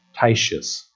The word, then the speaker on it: Titius: How do you pronounce T-I-T-I-U-F? Titius.